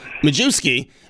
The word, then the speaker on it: Majewski: Majewski.